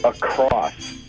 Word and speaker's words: across: Across. – Across. – People say, I'm going across the room. I don't like it when they put the T on the end of it.